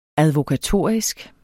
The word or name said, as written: advokatorisk